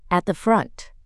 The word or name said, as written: at the front